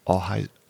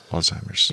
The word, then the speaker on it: Alzheimer's: Alzheimer's. Alzheimer's. Alzheimer's. – Alzheimer's.